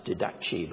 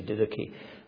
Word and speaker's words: Didache: Sometimes pronounced Didache, but it should be Didache, more correctly pronounced, which is a very early manual for worship, very early indeed, and it's there.